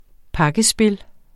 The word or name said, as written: pakkespil